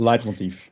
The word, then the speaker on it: leitmotief: Je hebt een thema en je hebt een leitmotief of leitmotief, hoe spreek je het uit? Ja, leitmotief.